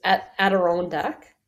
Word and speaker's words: Adirondack: It's Adirondack. Adirondack, and that's the American lingo on the end of it.